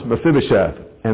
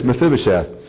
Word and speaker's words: Mephibosheth: In one case, he shows it to the tough name to pronounce, Mephibosheth. And that is not me mispronouncing the word. His name is Mephibosheth.